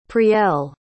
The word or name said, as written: priel